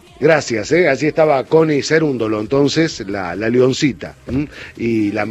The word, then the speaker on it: Cerúndolo: Connie, perdón que por allí, a ver, no sé la pronunciación, he escuchado en distintos medios Cerúndolo, por otro lado Cerúndolo, ¿cómo es en realidad el apellido? – Cerúndolo, con tilde en la U. – Cerúndolo, perfecto.